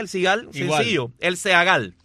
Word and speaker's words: Seagal: y Cigal se escribe S-E-A-G-A-L. – Ahí estamos, ahora sí. – Seagal, así. El Cigal.